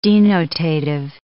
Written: denotative

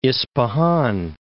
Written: Ispahan

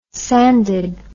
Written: sanded

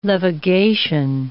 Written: levigation

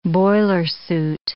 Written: boilersuit

